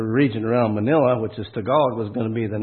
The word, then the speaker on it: Tagalog: It's T-A-G-L-A-G. Now, my natural tendency is to pronounce that Taglag. But no, it's Tagalog.